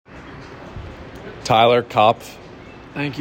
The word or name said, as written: Tyler Kopff